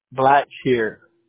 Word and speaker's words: Blackshear: Blackshear. B-L-A-C-K-S-H-E-A-R.